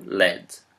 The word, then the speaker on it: lead: Outra palavra, L-E-A-D. A pronúncia dela pode ser tanto lead quanto led.